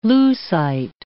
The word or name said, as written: Lucite